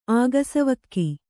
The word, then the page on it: ಆಗಸವಕ್ಕಿ: Kannada